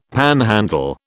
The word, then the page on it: panhandle: British English